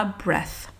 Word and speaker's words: breath: et après le son th tu l'écris, tu sais que euh, tu es censé le prononcer encore une fois en te mordant un petit peu la langue et si tu n'y arrives pas, ben, du mieux que tu peux pour que ça ressemble à breath.